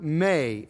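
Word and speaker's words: may (μή): The speaker or writer can use the little word ou, or he can use the word may, M with a long A, ou or may. Both of those words mean no or not, and both of them are used throughout the Greek New Testament.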